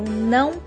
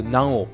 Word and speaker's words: não: できない場合は No, eu não posso。 – はい、できないので No という意味で No。 No は NAO と書いて A の上にニョロっていう。 – ニョロって中がついてます。 – 中っていう記号だそうですよ。はい、つくと No っていう発音になるんだそうです。 – はい。 – で、 eu não posso、できません。